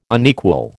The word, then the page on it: unequal: American English